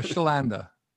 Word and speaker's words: Chlanda: Ryche, how do you say your last name? Because I didn't want to uh, mess it up. – Uh, Chlanda.